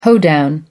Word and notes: hoedown: American English

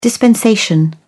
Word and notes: dispensation: British English

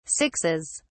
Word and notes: sixes: British English

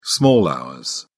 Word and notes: small hours: British English

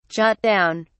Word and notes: jot down: British English

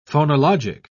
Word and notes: phonologic: British English

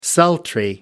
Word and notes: sultry: American English

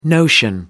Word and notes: notion: American English

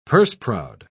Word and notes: purse-proud: British English

